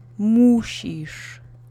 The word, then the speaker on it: musisz: Accent is on the first syllable. Musisz.